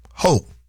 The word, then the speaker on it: Hochul: Emphasis on the first syllable. – Hochul.